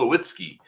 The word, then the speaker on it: Lawitzki: Today, we are joined by a German game development studio called Chasing Carrots, and we have today with us Paul Lawitzki.